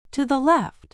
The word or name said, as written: to the left